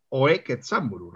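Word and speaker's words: Ōe Kenzaburō: Y luego, aunque todo el mundo le llama Ketsamburu Oe, premio Nobel de literatura, creo, y si no que algún japonés corrija, que es Oe Ketsamburu realmente.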